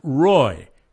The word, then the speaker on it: Roi: And then she added the words, if you were to spell them in English, it would be R-O-I. And of course, you can't spell that in English because it's Hebrew. But it's pronounced Roy.